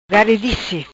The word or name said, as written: Garidice